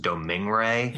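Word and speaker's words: Domergue: And you don't realize that until you understand her name is actually pronounced Domingue.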